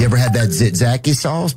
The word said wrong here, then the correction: tzatziki: Tzatziki? – He was trying to say tzatziki, and she said, say it again.